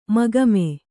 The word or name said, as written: ಮೊಖಾಮು